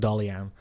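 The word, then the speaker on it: Daghlian: He's got an Irish surname. It's Daghlin, but I had to look it up phonetically. It's pronounced Dollyan.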